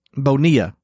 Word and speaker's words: Bonilla: Bonilla. – Yeah, you just pronounce the two L's like a Y.